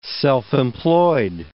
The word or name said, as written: self-employed